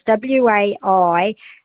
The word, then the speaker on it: Waialua: UA, and that is pronounced, so it's WAIALUA, and it's pronounced Waialua. Yeah, so if you put a like a WAI slash LUA, you'll remember it, Waialua.